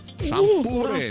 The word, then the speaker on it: шампуры: Итак, запомните, Олечка, и вы особенно, что везде, как бы вы там ни переворачивали, ударение во всех формах существительного падает на букву «У». Шампуры, металлические шампуры, жарить на шампуре, есть с шампура.